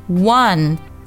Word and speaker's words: one: One 和 o'clock 要连读，1 o'clock. 注意 one 这个单词啊，不要读成中文里一万两万的万，一定要注意它有一个前鼻音的音，舌尖顶到上牙龈上去发 one o'clock.